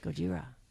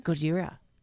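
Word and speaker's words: Gojira (ゴジラ): Gojira. – Say that again? – Gojira. – How do you know that word? – That's how you say it in Japanese.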